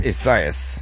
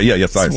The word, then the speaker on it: Isaias: Well, they did it phonetically. E with S-Y, then two high E's, and then a us. So, okay. Ezeus is the way they did it. Or okay. Isaias.